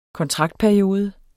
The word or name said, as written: kontraktperiode